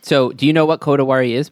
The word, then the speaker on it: Kodawari: All right, Professor Joseph Labriola, welcome to Exploring Kodawari.